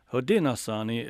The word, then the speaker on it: Haudenosaunee: How did I do on the pronunciation of Haudenosaunee? – You did well. – Is that close? – That's good. And it's kind of the, I would call it the Onondaga way of pronouncing it. Haudenosaunee is kind of our way of pronouncing it. This is Onondaga-Gwena, language of the people of the Great Hill. But, you know, either works.